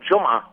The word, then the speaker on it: Jumah: And they call it, and how do you pronounce from the Arabic word, J-U-M-A-H? – That's the word Jummah, which means Friday.